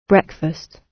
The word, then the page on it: breakfast: British English